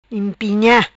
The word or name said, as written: Impinha